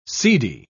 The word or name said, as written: seedy